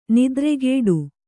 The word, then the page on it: ನೆತ್ತರುಗೊಡೆ: nettarugoḍe